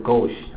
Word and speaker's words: gauche: A gauche remark is tactless. A gauche offer of sympathy is so bumbling as to be embarrassing. Gaucherie is an awkward, clumsy, tactless, embarrassing way of saying things or of handling situations. The gauche person is totally without finesse. 好，啊，French word，法文字，啊，这个左手这个词，这个法文字是什么？是 gauche，啊，念作 gauche 啊，来念一下 gauche，gauche，gauche，gauche，好。